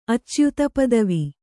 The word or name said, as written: ಅಚ್ಯುತಪದವಿ